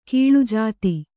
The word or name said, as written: ಗೋಳಜಾತ